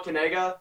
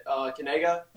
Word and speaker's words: Kanega: Oh, so this is how you pronounce it. It's Noah Kanega. You say it Kanega, like that.